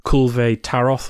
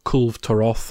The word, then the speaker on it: Kulve Taroth: They've had the uh, a special event called the Kulve Taroth or Kulv Taroth, however you want to pronounce it, Siege, mm-hmm.